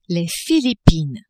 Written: Philippines

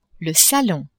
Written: salon

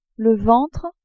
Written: ventre

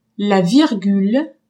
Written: virgule

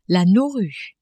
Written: Nauru

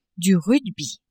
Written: rugby

